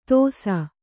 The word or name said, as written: ತೋಸ